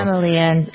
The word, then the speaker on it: Malia: Remember all the trouble that they had, I think, is it Malia? Is that how you pronounce her name? The 24-year-old? – Yeah, Malia.